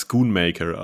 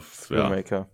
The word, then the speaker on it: Schoonmaker: Ich kenne ihn als Schoonmaker, aber. Schoonmaker. – Ja. – ja. – Okay, dann nehmen wir das so.